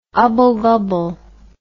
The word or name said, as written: ubble-gubble